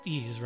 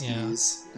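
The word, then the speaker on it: Ys: It is pronounced Ease, right? Not yeah. As far as I've ever known, it's Ease.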